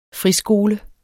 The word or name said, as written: friskole